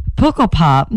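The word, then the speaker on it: Pukkelpop: Puckle Pop. – What is that? – Puckle Pop is a festival out in Belgium, and it's a super super great, really eclectic festival.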